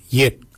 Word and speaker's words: yed: Nanak is spelled N-A-N-G-A-G. Nanak. Nanak is your neck. Chin. We say yet. Yet for chin. Yet is spelled Y-E-D. Yet. Yet is your chin. For throat, we say wort. For throat, we say wort. Wort is spelled W-O-R-D.